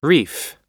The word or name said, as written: reef